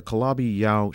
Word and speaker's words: Calabi-Yau: The Calabi-Yau, is that how it's pronounced? – That's right. – The Calabi-Yau shape, which is the six dimensions rolled up at the Planck level as such.